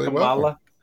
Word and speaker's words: Kamala: Kamala. – It's supposed to be like comma. Comma.